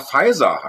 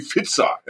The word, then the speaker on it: Pfizer: Ich habe jetzt erst gelernt, dass Pfizer Pfizer heißt. In meinem Kopf hießen die immer irgendwie Pfizer.